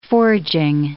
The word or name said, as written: forging